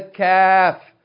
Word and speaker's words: kaf: The letter kaf, not qaf. Okay, so you have to pay attention that you don't pronounce kaf like qaf, or qaf like kaf.